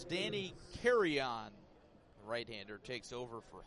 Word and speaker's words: Carrion: Carrion, C-A-R-R-I-O-N. But pronounced like you're taking a flight.